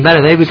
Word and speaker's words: Daredevil: Aquí somos capaces de pronunciarlo de cuatro maneras distintas. – Devil. Yo he dicho que voy a quedarme con Daredevil, que es como lo he hecho desde chiquitillo. Para los que los oídos se les pongan colorados, yo lo siento.